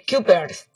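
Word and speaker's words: Cuypers: A ver, Kuipers, así se escribe. Es C-U-Y. – Kuipers.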